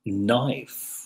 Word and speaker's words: knife: Knife, knife, no canif. Knife, but knife. Así que no pronunciamos la K, es prácticamente muda, ¿no?